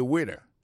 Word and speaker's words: waiter: Waiter, ça veut dire accent tombé sous premier syllabe là. Waiter, W-A-I-T-E-R. Moi, son garçon.